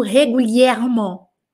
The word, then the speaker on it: régulièrement: Tem quatro es, esse advérbio régulièrement. E cada um desses es, por conta do acento, por conta da sílaba, ou por conta da nasal, ele tem um som diferente.